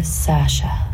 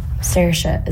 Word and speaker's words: Saoirse: It's Saoirse, but it's easier for Americans and English people to say Saoirse or Sasha. – What was the difference? – Saoirse is how Irish people say it.